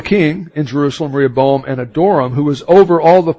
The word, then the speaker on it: Adoram: Then King Rehoboam sent Adoram. And that's, James, that's the way it's supposed to be pronounced according to the book we've got, you know. Adoram, that sounds like, you know, I kind of adored, talking about Adoram.